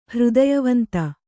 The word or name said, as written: ಹೃದಯವಂತ